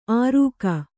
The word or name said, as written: ಅರುಗ